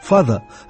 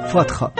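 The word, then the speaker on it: Fatha: Я до сих пор не могу понять, почему кличка Эрла Хайнса, папаша, фада, от упрощенного фазер, на Вики читается почти по-арабски «фатха».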